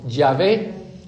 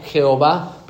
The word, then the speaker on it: Yahvé: Nosotros en la modernidad le incluimos dos vocales para poderlo pronunciar, la A y la E. Entonces leemos Yahvé.